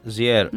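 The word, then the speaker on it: JR: Πώς τον προφέρει στον καλλιτέχνη. Ζιέρ. Ναι.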